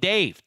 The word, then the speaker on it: Dave: That's code Dave, D-A-V-E only. Wait, not D-A-V-E.